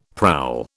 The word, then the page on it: prowl: British English